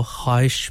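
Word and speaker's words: ख्वाहिश (خواہش): ख्वाहिश वैसे वाव लिखा जाता है खे वाव अलिफ हे शीन लेकिन साकिन है जी जो वाव है इसलिए इसको ख्वाहिश पढ़ा जाता है वाव साइलेंट है साकिन है उसको सोती असरात से दूर रखा जाता है लिखा जरूर जाता है लेकिन वाव को पढ़ा नहीं जाता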